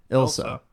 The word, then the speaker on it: Ilsa: How do we say her name? – Ilsa.